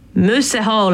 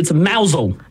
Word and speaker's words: Mousehole: Oh, it's actually mousel. – Mousel. – But it's spelt mousehole. – It is spelt like mousehole.